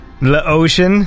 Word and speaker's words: L'Ocean: This one's called Suntan Lotion, but it's spelled L apostrophe and then the word ocean. – Suntan Lotion? – Lotion. Is that how you say it? – That's how I would say it.